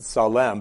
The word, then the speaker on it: Salem: I intentionally pronounce it Salem because that's how the Hebrew would have sounds.